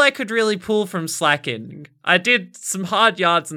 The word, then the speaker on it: Slaking: But what you might not have noticed about Slaking's name is that it is also a heteronym. A heteronym meaning a word that is spelt the same as another word but pronounced differently.